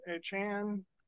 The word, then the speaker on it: Achane: Devon Achane, Achan. – It's Achan. – Achan is okay. The pronunciation. Achan.